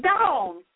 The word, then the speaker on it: Dawn: It's Dawn. D-A-W-N.